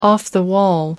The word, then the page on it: off-the-wall: British English